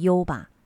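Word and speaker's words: 湯葉: 优吧呢，在日语当中写成汤叶，就是汤的那个叶子这两个字，念成优吧，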